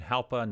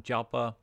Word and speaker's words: Jalpa: Now, that again, that's kind of like an H instead of a J pronunciation. Halpa, not Jalpa.